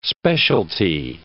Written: specialty

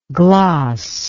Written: glossless

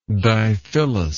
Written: diphyllous

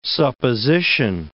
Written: supposition

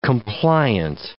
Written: compliant

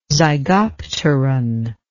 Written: zygopteran

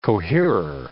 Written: coherer

